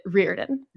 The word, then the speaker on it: Riordan: But he also said that he pronounces it Riordan.